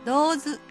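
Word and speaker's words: 堂頭: 春日井の面白地名、道図。漢字のお堂の堂に頭と書きます。道図は波佐間町の東側にある地名で、道図と読みます。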